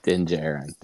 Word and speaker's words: Din Djarin: Old Din Djarin, or however you say his name. – Din Djarin. – Din Djarin.